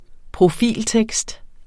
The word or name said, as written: profiltekst